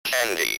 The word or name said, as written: candy